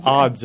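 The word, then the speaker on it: Adz: So the Age of Odds, it's A-D-Z. – Not O-D-D-S. – No, not O-D-D-Z.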